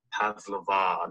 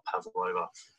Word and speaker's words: Pavlova: Another big thing I'd like to get out of the way is it's pronounced Pavlova, not Pavlova.